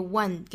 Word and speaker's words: one: 被这个 one 给欺骗了哈，因为它其实长得是一个元音字母的样子，但是发音是辅音打头的。